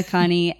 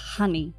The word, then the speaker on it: Khani: That's, I mean, one in thing Farsi, nobody says it like this. It's Khani. – Khani.